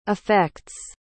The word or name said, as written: affects